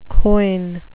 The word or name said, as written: quoin